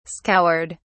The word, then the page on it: scoured: British English